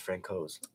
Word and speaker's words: Francouz: Also, I've noticed I say Francouz, you say Francouz. Jared Bednar says Frank Hoes. – Yeah, I know. – I and I was like, I thought we already settled on a soft C then.